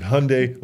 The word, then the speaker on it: Hyundai: When Hyundai came to America, people asked the CEO how he pronounced it, and he said Hyundai like Sunday.